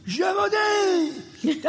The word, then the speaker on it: Gévaudan: And he puts his glass on and he goes, Gévaudan!